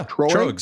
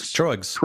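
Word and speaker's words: Tröegs: So this is I don't even know how to pronounce this. Is it Trogs? – Yeah, Trogs. Trogs. – Trogs. We'll go with that.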